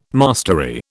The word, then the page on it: mastery: British English